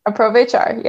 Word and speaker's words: Aprovechar: Aprovechar, yep.